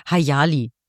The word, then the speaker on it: Hayali: Die heißt übrigens Hayali, wurden wir in einem der Kommentare mal auf YouTube ja, hingewiesen. Aber an der Stelle muss ich sagen, wenn ich Dunja Hayali falsch ausspreche, da bricht mir kein Zacken aus der Krone.